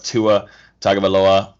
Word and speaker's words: Tua Tagovailoa: And then number five pick, obviously, Miami Dolphins are going to go with Tua Tagovailoa.